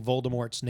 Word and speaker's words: Voldemort: And by the way, we did forget to discuss about how we're pronouncing Voldemort's name wrong last week. – Yeah, we're J.K. Rowling said it's a silent T. – Yep. – So it's Voldemort.